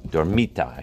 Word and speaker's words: Dormita: Dormita, actually, it's pronounced. That's the Aramaic word for sleep.